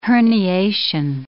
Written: herniation

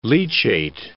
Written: leachate

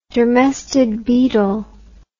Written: dermestid beetle

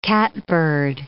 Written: catbird

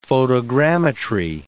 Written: photogrammetry

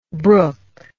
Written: bruch

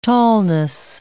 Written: tallness

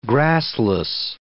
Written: grassless

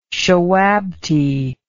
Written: shawabti

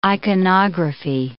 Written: iconography

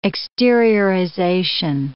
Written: exteriorization

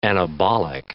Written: anabolic

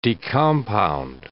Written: decompound

